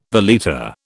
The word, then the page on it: valeta: British English